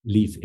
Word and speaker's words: Leif: Find my name, Leif Hetland. It's spelled L-E-I-F, like Leif Erikson.